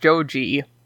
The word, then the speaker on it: Joji: Chapter 2 opens on a 15-year-old Joji Joestar, spelled like George Joestar from the last chapter is spelled, but in Japanese it's pronounced Joji.